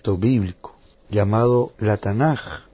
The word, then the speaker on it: Tanaj: Sin embargo, la última letra en hebreo tiene dos pronunciaciones, una pronunciación de K y una pronunciación de J. Por tanto, se toma la segunda pronunciación y el acróstico sale como Tanaj.